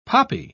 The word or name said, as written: poppy